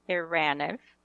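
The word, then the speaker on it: Eranniv: Eraniv, that's the correct pronunciation. – Eraniv.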